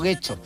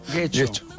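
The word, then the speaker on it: Getxo: Que no, Guecho no, Guecho. – Guecho. Exacto, es donde me equivoco yo, que lo vuelvo U.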